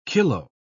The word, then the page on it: kilo-: British English